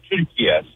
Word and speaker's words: Türkiye: The way Turkish people pronounce it is actually the way it said Türkiye.